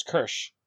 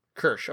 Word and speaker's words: Qirsh: I'm pretty sure it's Kirsch. – Kirsch, okay. – Q-I-R-S-H.